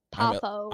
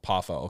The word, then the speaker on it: Poffo: Yes, RIP to the how do you say his last name again? Paul Poffo. – I'm illiterate. Paul Poffo.